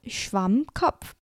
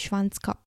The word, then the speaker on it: Schwammkopf: 所以呃，这个我这个字印象很深刻，而且这个字真的很难念。我觉得 Schwammkopf 很难念，Kopf 这个字本来就很难念。Kopf 这个字是头，就是 K O P F，K O P F，Kopf。然后这字本身就已经很难念了，因为你知道 P P，你知道 P 跟 F 一起的，那超难发音。反正就是。对，请大家记得是 SpongeBob Schwammkopf，不是 SpongeBob Schwanzkopf。